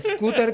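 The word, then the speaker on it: escúter: Scooter con acento en la U, según la Real Academia Española. con E al principio. Scooter. Con E, e. Scooter.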